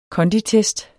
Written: KONDITEST

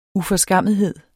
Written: uforskammethed

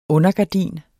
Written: undergardin

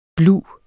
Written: blu

